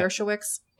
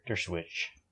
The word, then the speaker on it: Dershowitz: Um, Der- Dershowitz, is that how you say his name? Alan yeah. Dershowitz? Der- Dershowitz.